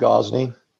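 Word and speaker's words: Gozney: is that how to pronounce that right? – It's pronounced Gozeni.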